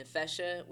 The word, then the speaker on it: NeFesha: Everything is Lauren, L-A-U-R-E-N, and then NeFesha, which is N-E-F-E-S-H-A.